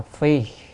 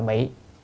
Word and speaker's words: phi: Thì ở đây đoạn này chữ này đọc là phi, đem quần áo mặc vào người đó, không đọc là bị đó, mà phi